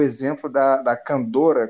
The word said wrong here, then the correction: candura: candorra, você me, me corrige se eu estiver falando errado aí, que é aquela vestimenta. Candura. Candura. – Candura.